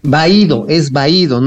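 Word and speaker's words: vahído: Les dije que te dio un váguido transitorio. Vaído, es vaído, no es vaquido. se lo pronunció mal el presidente.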